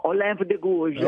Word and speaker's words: Olympe de Gouges: Cioè, però alcune sono, cioè se io vi dicessi, adesso lo pronuncio malissimo, Olympe de Gouges o de Gouges. – No, Olympe de Gouges.